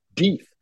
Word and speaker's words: Deeth: I mean, Puckerbrush, Gabs, Scotty's Junction, Blackjack, Carp and Duckwater, Fatty Martin Lake, Toe Jam Mountain, Winnemucca, Stagecoach, Steamboat, Lovelock, Deeth, which rhymes with teeth, Tunnel, Sulphur, Pine Nut, Slim Creek, and Adverse.